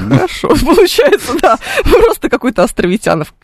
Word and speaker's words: Островитянов: Он на самом деле Островитянов. Но поскольку абсолютно все, кто видит это название, произносят Островитянова, то у нас произошла удивительная, удивительная биполярочка в этом смысле. Потому что академик остается Островитяновым, а вот улица официально в словарях Островитянова.